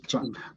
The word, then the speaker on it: Chawn: Help me again. Chawn Bracey, right? – Chawn. Thanks. Yep. – Chawn. Okay. Chawn.